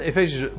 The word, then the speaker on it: Efezische: Door of vanuit de Efezische. zeg ik het goed? Ja, de Efezische bevolking zelf.